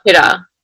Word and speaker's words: Hira: like in general, say like how I pronounce my name versus how other people, but you know, everyone else calls me Hira, but my name is Hira, but people can't tell the difference.